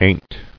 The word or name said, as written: Ain't